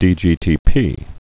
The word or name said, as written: dGTP